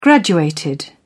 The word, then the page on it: graduated: British English